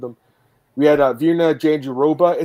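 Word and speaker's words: Jandiroba: It's not Janjidoba. I heard your pronunciation, or is it Drake Riggs or someone? Is that who it was? They like had yeah. a clip of her saying it. I don't know why Jon Anik says it's like Janjidoba because she herself said Janjiroba, and I'm like, well, that's what it, that's how it's spelled.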